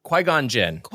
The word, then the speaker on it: Qui-Gon Jinn: Qui-Gon Jinn? – Qui-Gon Jinn.